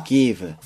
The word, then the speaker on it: give: Give. – V 발음 잘 해주세요.